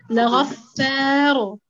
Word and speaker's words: لغفار: نعم هي ساكنة وما قبلها ساكن وما قبلها مفتوح. لذلك تنطق مفخمة لغفار.